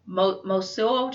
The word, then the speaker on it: Mosuo: Mosuo China, M-O-S-U-O? – Yeah.